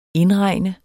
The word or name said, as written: indregne